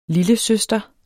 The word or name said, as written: lillesøster